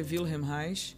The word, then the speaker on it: Wilhelm Reich: Quem fala muito bem sobre isso, eu sempre dou dica desse livro, é o Wilhelm Reich, ou Wilhelm Reich, que, mas a pronúncia correta é Wilhelm Reich,